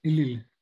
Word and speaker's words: Ellul: Ellul? – Ellul.